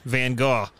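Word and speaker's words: Gogh: If you're talking about the artist, you do. Van Gaw. That's how it's pronounced, actually.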